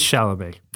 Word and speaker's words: Chalamet: If you want to watch a good Timothée Chalamet movie, I still don't know that that's actually how you pronounce his name. I guess it probably is. It can't be Chalamet. – Chalamet, right? – Chalamet. There you go.